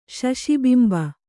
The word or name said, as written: ಶಶಿಬಿಂಬ